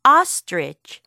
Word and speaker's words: ostrich: 타조는 영어로 ostrich, ostrich. 라고 해요. 조금 발음이 어렵죠. Ostrich. 우리 친구들이 신경 써야 될 부분은 바로 첫 소리 부분이에요. 거기에 O, O. 이 O 소리가 들어가 있거든요.